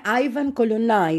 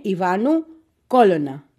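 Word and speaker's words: Ivanu Colonna: Τον γράφουν Άιβαν Κολωνά οι Γάλλοι, αλλά η σωστή προφορά, γιατί ο άνθρωπος είναι κορσικανός, είναι Ιβάνου Κόλωνα.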